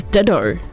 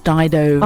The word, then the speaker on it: Dido: I always thought it was Ditto. – No, it's Dido.